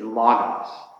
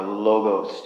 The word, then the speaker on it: logos: The word in Greek is logos, or logos, depending on who you are and how you pronounce an O, or an omicron, in the center of the word. So, L-O-G-O-S, okay?